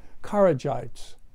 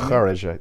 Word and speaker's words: Kharijite: In fact, not too long after its inception, in the form of the Kharijites. The Khawaraj, you can pronounce that correctly for me. – Kharijite. – Kharijite, okay.